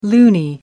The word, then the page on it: loonie: British English